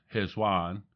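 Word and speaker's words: Cheshvan: Chesvan, or pronounced Heswan, the eighth month, is not mentioned by name in the Bible.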